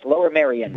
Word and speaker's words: Lower Merion: Senator Arlen Specter has said that the use of Lower Merion, is that how you pronounce it? – Yes, Lower Merion.